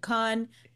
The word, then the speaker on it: Cannes: Am I saying it wrong or is it can? It is officially can.